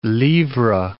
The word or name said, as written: librae